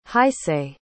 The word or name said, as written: hisaye